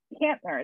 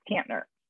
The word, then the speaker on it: Kantner: And it's Kantner. It's the German pronunciation, an end on the front and the back side of a T is Kantner.